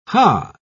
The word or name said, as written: hah